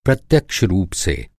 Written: प्रत्यक्ष

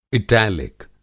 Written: इटैलिक

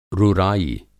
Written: रुराई

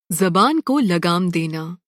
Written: ज़बान

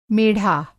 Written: मेढ़ा

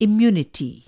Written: इम्युनिटी

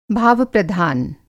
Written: भावप्रधान

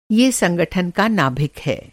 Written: नाभिक

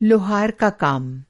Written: लोहार

